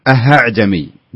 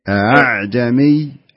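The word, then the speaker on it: أأعجمي: يعني ما يصلح نقول أعجمي ولا يصلح نقول أهعجمي، بينهم أعجمي أعجمي، كأنك إيش، كأنك تنطق الهمزة بدون تحقيق،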